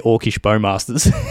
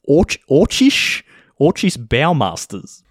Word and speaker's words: Orcish Bowmasters: He has no idea how to say Orcish Bowmasters. continues to pronounce it Orcish Baumeisters.